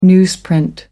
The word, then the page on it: newsprint: American English